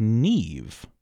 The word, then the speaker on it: Niamh: So N-I-A-M-H, kind of a lovely name, but it's pronounced Neve, right? And, yes. – And as you just mm-hmm. told me, that M-H turns into sort of a mm-hmm. V sound.